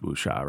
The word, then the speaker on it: wuxia: All these, it's pronounced wuxia, right? – Wuxia, wuxia yeah, yeah.